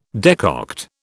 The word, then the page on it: decoct: British English